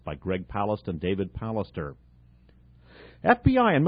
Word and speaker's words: Palast: This is by BBC journalist Greg Palast, P-A-L-A-S-T, or Palast, I've heard it pronounced.